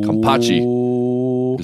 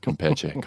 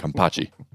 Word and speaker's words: kampachi: It's kampachi is the name of that fish, not kampeche, kampachi.